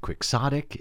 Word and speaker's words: quixotic: He says the term originates from Don Quixote, so the pronunciation is quixotic.